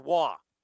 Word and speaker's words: Roy: And I still can't figure out how R-O-H is pronounced Waugh. – No, it's R-O-Y. It's spelled Roy, but it's pronounced R-O-Y.